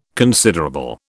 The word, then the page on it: considerable: British English